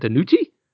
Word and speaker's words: DiNucci: What, how do you even say that, DiNucci? – Yes, that's correct.